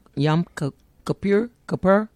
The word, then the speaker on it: Yom Kippur: September the 21st is Yom Kippur.